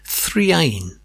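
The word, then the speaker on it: 3'ain: And a trio with an unusual name, Threain. That's three apostrophe A-I-N. It's a name based around an Arabic letter, which in shape is similar to the number three, but backwards, and phonetically pronounced Ain.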